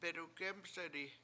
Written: bedugEmseti

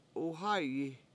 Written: 7uhA7yi

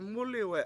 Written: MUUliwe7

